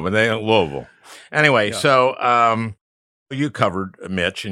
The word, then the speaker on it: Louisville: Louisville. But at least I said it right. – Say it properly. You have to go Louisville. – Louisville.